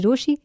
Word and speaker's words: Rochie: मेरा नाम है मिर्ची रोशी नाम की स्पेलिंग है ROCHIE.